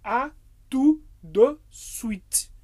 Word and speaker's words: à tout de suite: E por último, temos o à tout de suite. À tout, não pronunciamos o T, de, porque o E no francês tem som de E, então fica de suite.